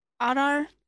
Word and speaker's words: Adar: Oh, well, getting a closer look at how they pronounce it, Adar, father in Elvish.